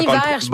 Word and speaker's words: Bon Iver: Bon Iver, je me rappelais.